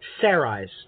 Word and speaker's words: Sarai: So, what do you think about Sarah's choice? Well, I said it incorrectly again. Sarai's choice.